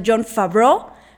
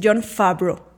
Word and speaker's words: Favreau: Yo siempre he pronunciado John Favreau, pero he visto en entrevistas que él ha dicho que es John Favreau.